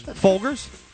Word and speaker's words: Folgers: Folgers. – Yeah, you know, not quite. But is that how you say it? – No. – How do you say it? – Folgers.